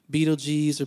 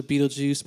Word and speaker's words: Betelgeuse: All right, the second star we're going to look at is called Betelgeuse or Betelgeuse, depending on how you want to pronounce it.